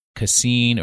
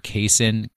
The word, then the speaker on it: casein: The other type of protein you want to invest in is, and everybody always says I pronounce this wrong, it's casein or casein, C-A-S-E-I-N.